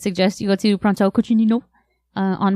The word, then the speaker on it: Cucinino: But it's cucinino. – Cucinino. – It's spelled C-U-C-I-N-I-N-O. – C-U-C-I-N-I-N-O. – Nino. – N-I. Cucinino.